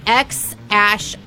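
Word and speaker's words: X Æ A-Xii: So they, A-E, its pronunciation is Ash. So it would be X Ash Archangel would be his name, is how you pronounce it.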